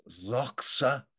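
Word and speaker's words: Doxa (δόξα): Doxa is how they say it.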